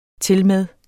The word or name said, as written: tilmed